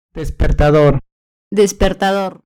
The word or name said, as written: despertador